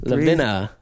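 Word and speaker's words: Lavina: Levinana. – No, it's just Levina. – Three syllables. – Levina. Levina. Levina Murphy.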